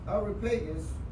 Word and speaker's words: Areopagus: And they took him and brought him unto Areopagus.